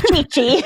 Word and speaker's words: Chi-Chi: Is it Sissy? – No, it's Chi-Chi, but I like Jason Sissy. – Jason, I apologize for the mispronunciation.